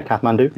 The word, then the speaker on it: Kathmandu: I was born in Kathmandu. Is that right? A pronunciation? – Yeah, Kathmandu, Nepal.